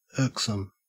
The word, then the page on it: irksome: Australian English